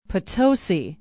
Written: Potosi

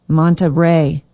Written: Monterey

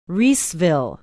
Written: Reeseville

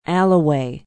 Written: allouez

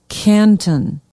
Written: canton

